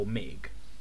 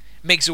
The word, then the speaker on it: MiG: I say you don't say MIG, you say MiG. – Yeah. Yeah. – Which is how you pronounce big M, small I, big G.